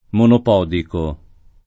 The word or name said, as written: monopodiale